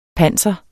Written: panser

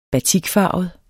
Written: batikfarvet

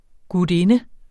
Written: gutinde

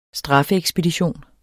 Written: straffeekspedition

